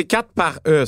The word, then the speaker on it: 4xe: Ben c'est 4 par e.